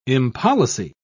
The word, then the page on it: impolicy: British English